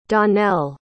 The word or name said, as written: dawnelle